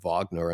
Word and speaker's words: Wagner: I knew it was Mozart and not Mozart, and you know, Wagner and not Wagner.